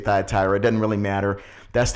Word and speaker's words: Thyatira: And we're in the book looking at the church of Thyatira, or really Thyatira. It's really, there's really no long I's, and I shouldn't say in Greek.